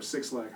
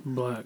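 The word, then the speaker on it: 6LACK: If, basically, if you're like, yo, I want to do some shit like Black or Six Lack, how the fuck you say his name? – Black.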